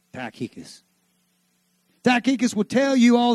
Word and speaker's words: Tychicus: Tychicus That's the way I've heard it pronounced. You've heard it pronounced Tychius and all kinds of stuff, but you can go, but Tychicus I like, because that's Texan.